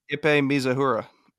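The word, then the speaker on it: Ippei Mizuhara: Oh, Ipepe Mizuhara. Is that how you say it? – Ipe Mizuhara.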